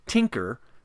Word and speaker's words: Tynker: When you visit Tinker, That's spelled T-Y-N-K-E-R.